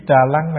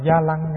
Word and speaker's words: Trà Lăng: Cái chữ Trà Lăng này có nơi người ta gọi là Gia Lăng nha, Gia Lăng. Đó, hai cách đọc.